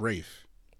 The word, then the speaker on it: Ralph: Yeah, but his first name is pronounced Rafe.